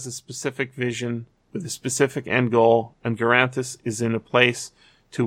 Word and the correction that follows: Garthenis: I'm saying that name wrong. Garthenis is how I want to Garthenis.